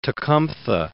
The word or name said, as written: Tikamthe